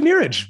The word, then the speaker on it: Neeraj: It's actually Neeraj, but you were close.